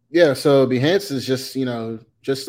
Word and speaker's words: Behance: I think it's, was it Behance? Is that how you pronounce it? – Yeah, Behance.